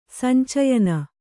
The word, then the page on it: ಸಂಚಯನ: sancayana